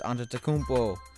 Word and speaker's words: Antetokounmpo: And so who do I think should win MVP between James Harden and Giannis Antetokounmpo? And that, yes, I said that right.